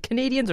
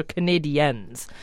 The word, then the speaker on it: Canadiens: Is it Canadians or Canadiens? – Canadiens. – Canadiens. Let's go with the Quebecois pronunciation.